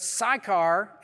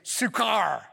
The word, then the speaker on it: Sychar: But Sychar, and if you want to pronounce it in the Hebrew way, you'd say Sukkar.